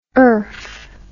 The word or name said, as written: erf